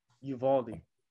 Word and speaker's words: Uvalde: like Eudela or Uvalde. – Uvalde.